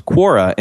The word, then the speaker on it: Quora: All right, so what is Quora? – All right, Quora.